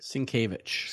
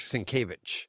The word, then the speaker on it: Sienkiewicz: Sienkiewicz. – Sienkiewicz.